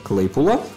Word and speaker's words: Claypool: Claypool, ну это фамилия Леса Клейпула.